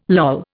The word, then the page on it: loll: Indian